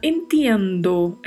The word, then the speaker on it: entiendo: Particularidade de pronúncia aí, este TI, né? Entiendo. Como já mencionamos em outros podcasts, a pronúncia é ti e não ti. Então falamos entiendo.